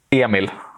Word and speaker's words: Emil: Emil Abrahamsson is like how you would pronounce it in Swedish, and I don't know if it's Emil, Emil, Emil. It's like, I've heard all types and I don't even know myself. So go with whatever you feel like.